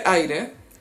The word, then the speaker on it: Aire: Ah, a propósito, eh, mostró foto de su guaguito que se llama, se escribe Aire. – Aire. – Aire Jordan, Aire Jenner. No, eh, Aire, ¿cómo le, le, irán a decir? – Air. Air.